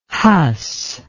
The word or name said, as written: Huss